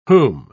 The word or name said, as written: whom